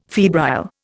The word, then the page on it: febrile: Indian